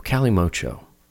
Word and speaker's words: Kalimotxo: Yeah, so it's spelled the quote-unquote correct spelling, Kalimotxo. – Whoa. – But it's sort of more kindly spelled as Cali, like California, M-O-C-H-O, Kalimotxo.